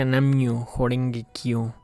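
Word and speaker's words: Nam-myoho-renge-kyo: Ese mantra se pronuncia Namyo Horenge Kyo.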